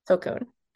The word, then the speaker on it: Tulkun: I want to see the Tulkun, or how do you say it? – Tulkun.